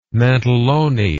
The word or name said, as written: mantellone